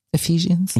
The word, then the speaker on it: Ephesians: But they had us read Ephesians. Ephesians. – You said it right.